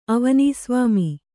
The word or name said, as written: ಅವನೀಸ್ವಾಮಿ